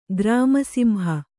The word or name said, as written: ಗ್ರಾಮಸಿಂಹ